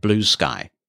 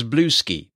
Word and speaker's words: Bluesky: One of those attempts, which has been getting a bit of attention of late, is called Blue Sky. That's how it's pronounced but it is all one word and there's no mixed case. So when your screen reader says it, it may well pronounce it as Blueski.